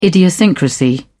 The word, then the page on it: idiosyncrasy: British English